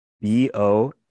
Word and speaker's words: Bologne: I think it, Bologna. I don't want to butcher the name, but it is in the article. Joseph Bologne.